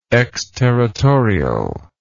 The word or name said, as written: exterritorially